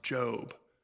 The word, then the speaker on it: Job: I'm talking right now, and he goes, it's pronounced Job, and I went, oh, dude, it's okay. I just found this part two. There's not an E on the end. His name's actually Job.